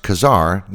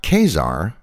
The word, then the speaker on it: Khazar: And yes, it's pronounced Khazar, not Khazar, as I have previously mispronounced it in previous episodes.